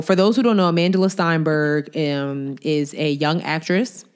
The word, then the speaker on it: Amandla: So Amandla Steinberg. – How do you say her name? – Amandla. – Amandla? – Yeah. For some reason, they added an L to her name.